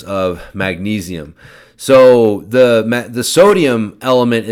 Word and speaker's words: LMNT: So L-M-N-T. So when I first saw it, I thought it was pronounced lemon tea. And so I still call it lemon tea, although I think it's supposed to be pronounced element. That is the play on letters there, I guess, if you will.